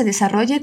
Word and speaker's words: desarrolle: Fíjate en la pronunciación de este verbo. Desarrolle. Hay doble R y doble L.